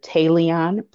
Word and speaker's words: Tayleon: So on Facebook, you can find me under Tayleon Puryear, and that's spelled T-A-Y-L-E-O-N, and my last name Puryear, P-U-R. Y-E-A-R.